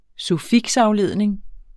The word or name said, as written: suffiksafledning